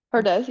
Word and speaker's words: Herdez: How did you pronounce it? – Herdez, yeah.